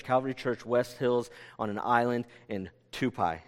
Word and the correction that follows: Tupi: And so we, did I say that right? It's Tupi. Tupi. Take all that back.